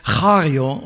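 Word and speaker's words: chario (χαίρω): It's kario. Actually, it's pronounced chario officially.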